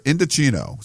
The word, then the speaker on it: Indochino: And before we jump into that, though, I wanted to thank our new sponsor, Indochino. So I-N-D-O-C-H-I-N-O, pronounced Indochino.